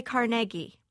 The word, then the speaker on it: Carnegie: In Pittsburgh, they still say Carnegie, I think. They pronounce, they say Andrew Carnegie.